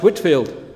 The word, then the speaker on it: Whitefield: You read it as Whitefield, but pronounced Whitfield.